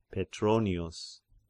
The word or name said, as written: Petronius